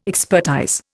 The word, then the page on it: expertise: Indian